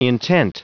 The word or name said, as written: intent